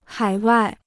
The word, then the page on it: 海外: hǎi wài